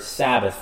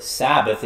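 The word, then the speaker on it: Sabbat (שבת): Now, before we look at the forbidden work, we need to look at the word Sabbath for a bit. If you go back and you look at the Hebrew, the word Sabbath is probably more correctly pronounced Sabbat. There's no TH sound, it's just Sabbat.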